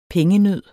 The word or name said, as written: pengenød